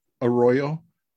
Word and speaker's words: Arroyo: Tita, T-I-T-A, and I don't know how to say it. It's Arroyo, A-R-R-O-Y-O. I'm sure I don't. – Arroyo? – Arroyo, probably.